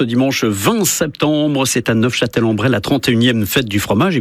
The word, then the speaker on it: Neufchâtel-en-Bray: Et en effet, pour les puristes, dont je ne suis pas, puisque je n'ai pas prononcé le F, mais pour les puristes, en effet, il faut prononcer Neufchâtel-en-Bray pour, en effet, peut-être se différencier de la ville de nos amis suisses.